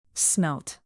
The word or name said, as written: smelt